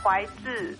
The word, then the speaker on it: Huai Tsu: Sí, porque en realidad se pronuncia Huaitzu.